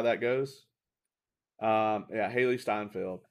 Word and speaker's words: Hailee Steinfeld: is it Hailee Steinfeld? Is that the pronunciation? – I think so. – Yeah, we can fact check that real quick. Yeah, so let's see. Yeah, so she is going to be played. Yep, I was correct.